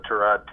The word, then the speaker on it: Tyrod: I didn't have to do too much thinking after I realized the value of Tyrod Taylor. Now, we learned a lot about Taylor during the training camp. One, as we learned how to pronounce his name, it's Tyrod, not Tyrod.